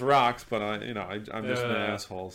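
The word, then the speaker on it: Roch's: They call it's called Rocks, but it's spelled ROCH. So I call them fucking roaches.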